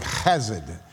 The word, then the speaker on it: chesed: It's the only one of two Hebrew words I actually pronounce correctly. It's the word chesed, and that's that love that won't let go.